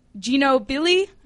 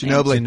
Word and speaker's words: Ginobili: Manu Ginobili. I can never pronounce his last Ginobili.